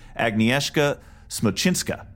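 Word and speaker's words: Agnieszka Smoczyńska: The name of the director would be pronounced akin to Agnieszka Smoczynska. And Chad was pretty close.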